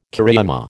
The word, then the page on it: cariama: American English